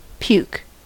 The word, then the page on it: puke: American English